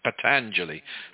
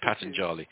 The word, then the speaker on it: Patanjali: Traditionally, to find that, you'd have to go to the aphorisms of Patanjali. I don't know if some people pronounce it Patanjali, but I say Patanjali.